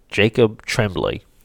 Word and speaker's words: Tremblay: who's like Justin Turtle Tom or Turtle Bomb. Uh, I am butchering that dude's name, Jacob. I'm sorry, Jacob Tremblay, who is phenomenal who is the real actor in this movie.